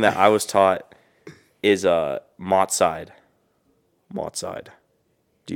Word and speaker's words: Mahlzeit: Mahlzeit. – Oh, Mahlzeit. – Mahlzeit.